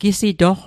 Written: gisitoq

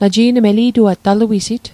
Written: eli'tuat

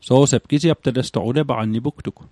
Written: apt'testoq

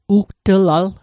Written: ugtlal

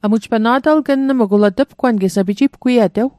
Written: pijipgwiaq

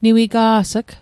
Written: niwiga's'g

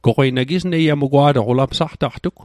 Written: neiamugwa'toq

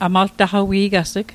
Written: amaltaqawi'gas'g

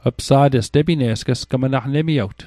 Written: tepine's

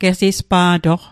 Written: gesispa'toq

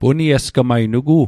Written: esgmat

